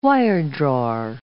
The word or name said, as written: wiredrawer